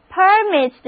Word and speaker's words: permit: 这里需要注意的是，如果我们把重音放在第一个音节上，单词读作 permit 的时候，这个单词就变成一个名词，意思是通行证、执照，意思是允许的名词。